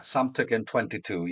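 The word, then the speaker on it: Samtökin: Yeah, Samtokin 22, yeah.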